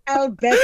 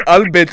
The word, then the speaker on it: Albert: Is it the Royal Albert Hall, just like just like Adele pronounces it? How does Pamela Nomvete pronounce it? – Royal Albert Hall. – Like Albert Lutuli.